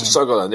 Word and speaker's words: Sogalan: Sogalan, am I pronouncing that right? How do you pronounce her? – It's Sogalan. There's no stress on any syllable.